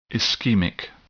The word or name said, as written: ischemic